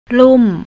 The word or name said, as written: lum